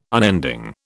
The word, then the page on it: unending: British English